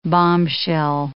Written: bombshell

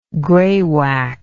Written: greywacke

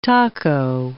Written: taco

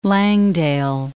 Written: Langdale Pikes

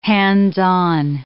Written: hands-on

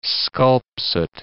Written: sculpsit

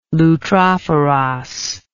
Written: loutrophoros

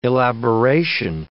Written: elaboration

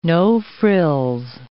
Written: no-frills

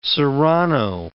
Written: serrano

